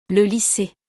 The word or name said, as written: Le lycee